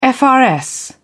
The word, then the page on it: frs: British English